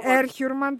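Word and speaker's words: Erhürman: Ναι, είναι Ερχιούρμαν τελικά στο, ε. – Μπράβο, είναι, ναι, γιατί Ερ... Ναι. Ερ σημαίνει στρατιώτης, χιούρ σημαίνει ελεύθερος και κατάληξη μαν. Και όταν είναι τρεις συλλαβές, τονίζεται πάντα στην πρώτη.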